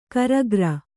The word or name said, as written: ಕರಿಕಾರೆ